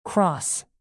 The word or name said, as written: cross